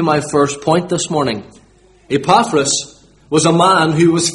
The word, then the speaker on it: Epaphras: I should say from the beginning that the correct pronunciation of this man's name is Epaphras. Epaphras. But I'm from Limavady. So for the duration of this sermon, he's going to be referred to as Epaphras.